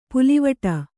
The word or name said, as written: ಪುಲ್ಲಿವೆಟು